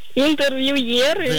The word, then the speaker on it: интервьюеры: Данное слово пришло к нам из французского, в котором, как известно, ударение ставится на последний слог. Поэтому правильно говорить еще раз. Как? – Интервьюеры. – Да, интервьюеры на «е».